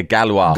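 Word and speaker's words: Gauloises: puffing away on his Gauloise, is that how they pronounce it? – Yeah, Galois.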